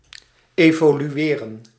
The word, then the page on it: évoluer: France; [e.vɔ.lɥe]